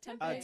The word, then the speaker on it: Tempe: Is it Tempe? – Yeah, Tempe. – Tempe, okay.